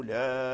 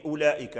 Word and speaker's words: أولئك: هذا الواو الذي أبدله وارسون ليس هو الواو المكتوب المرسوم في أولئك، لأن هذا الواو ديال أولئك لا يقرأ، أولئك ما تقولش أولئك، فتقول أولئك أولئك، هذا الواو لا يقرأ، إنما هو ثابت في الرسم لكنه غير ملفوظ به، أي لا تلفظ به.